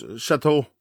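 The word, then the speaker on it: château: Yes, Chateau.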